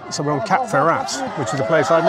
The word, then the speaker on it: Cap-Ferrat: For those people listening who can speak French, they might be recoiling at your pronunciation of Saint-Jean-Cap-Ferrat. One does not pronounce the T there.